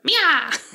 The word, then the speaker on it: Maya: And so I decided, like, her name's spelled like M-A-Y-A, which should be Maya, but she pronounces it Mia.